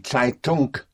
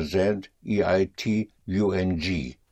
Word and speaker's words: Zeitung: Die Zeitung would be the newspaper. Spelled Z or Z, E-I-T-U-N-G. Die Zeitung. And the N-G in German, unlike in English, the N-G is pronounced. Zeitung. The G comes out, die Zeitung.